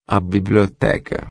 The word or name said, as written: biblioteca